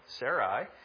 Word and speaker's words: Sarai: And yes, I'm pronouncing that correctly. She's not Sarah yet, just so you know, Sarai.